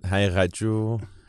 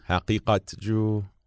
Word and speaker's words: Haqiqatjou: Yeah, you can pronounce it Haqiqatjou, Haqiqatjou. – All of them. – All of them are fine.